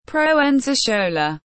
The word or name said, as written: Proenza Schouler